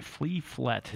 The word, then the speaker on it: Fliflet: Flea. Flea. Like a flea. – Yeah. – And then Flet. – Fliflet. – Yeah. – But it's spelled F-L-I. Fliflet.